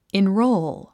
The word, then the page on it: enroll: inróul; インロォーゥ